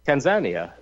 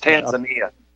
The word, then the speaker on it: Tanzania: It's Tanzania.